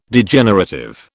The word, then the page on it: Degenerative: British English